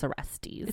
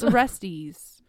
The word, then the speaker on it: Orestes: Orestes, Electra. That was an easy one. – I really hope someone comes at you and is like, that's not how you pronounce Orestes. – It's Orestes. – Orestes? – Orestes.